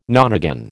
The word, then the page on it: nonagon: American English